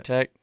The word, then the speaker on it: Wojtek: Wojtek? Like with a V?